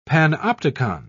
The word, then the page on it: panopticon: British English